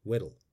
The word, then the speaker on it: Weddell: and saw me reassessing my previously pompously confident pronunciation of Weddell, which Neil Oliver says as Weddell.